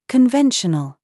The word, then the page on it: VERVE: British English